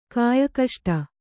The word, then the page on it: ಕಾಯಕಷ್ಟ: Kannada